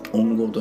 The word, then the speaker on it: 音響: 音響と書いてですね音の響きと書いて音号と読む